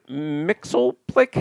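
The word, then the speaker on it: Mxyzptlk: Mr. Mixelplick, how do you pronounce Superman's, is that it? – Oh, yeah.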